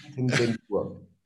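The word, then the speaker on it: Superintendentur: Und äh, wir beide haben uns darüber ja schon ausgetauscht, dass die Kirche, die jetzt die Kirche am Sitz ihrer, wie heißt das, Superintendantur, ist das richtig Intur. ausgesprochen? – Intur. Superintendantur. Ja,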